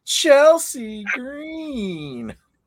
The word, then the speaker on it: Chelsea Green: You went to Kelsey Green. – You got to say her name right. If you don't say it like Samantha, you don't say it right.